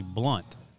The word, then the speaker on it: Blount: Well, you know, it looks like Blount. It's actually Blunt.